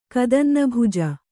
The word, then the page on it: ಕದನ್ನಭುಜ: Kannada